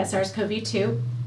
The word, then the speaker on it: SARS-CoV-2: Did I say that correctly? Yeah, SARS-CoV-2.